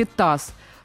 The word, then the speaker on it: ТАСС: Они произносятся не по буквам, они как раз читаются. Э, та же самая ВАК, да, э, Высшая аттестационная комиссия, или э, там ВОК, да, Всероссийское общество глухих, или ТАСС. ТАСС.